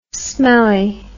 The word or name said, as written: smellie